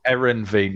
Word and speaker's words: Heerenveen: And obviously the playing spells, you've mentioned Newcastle, Spell it, Heverine, Feyenoord, AC Milan, Stuttgart. – Whoa, I got away with it. – Have another go. – Heverine. – There's an N in the middle. – Heerenveen, yeah, whatever.